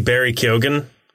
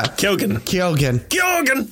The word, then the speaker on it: Keoghan: Barry Keoghan? That's how you pronounce his name.